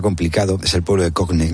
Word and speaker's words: Cogne: Para que nuestros ponedores se sitúen, Cogne es, que lo has pronunciado muy bien, es un pueblo paradisíaco en el norte de Italia, en plenas montañas, como en un valle de montañas.